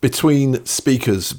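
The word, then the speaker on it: BTWN SPKRS: Between speakers, BTWN SP KRS, that's how they pronounce their name.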